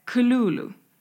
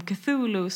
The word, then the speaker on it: Cthulhu: Ja, men det ska uttalas på ett så mörkt och alienaktigt sätt. Så att jag kan bara försöka få till uttalet. Men jag vet inte om jag får till rätt tonläge. Men det är Cthulhu. Så att mycket mer H i. Men eftersom det är mest känt som Cthulhu så är det det jag kommer fortsätta kalla det för.